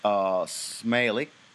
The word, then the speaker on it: Smaili: It gets a victory over Eunice Smaili, S-M-A-I-L-I. Smaili, I believe it's pronounced.